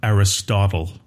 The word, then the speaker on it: Aristotle: Yeah, in English, I think English is one of the only languages where we don't pronounce the final vowel. So in English, we usually just call him Aristotle.